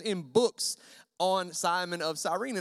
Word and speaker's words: Cyrene: There's many, many pronunciations for that last name, and I'm going with Cyrene, so judge me if you want.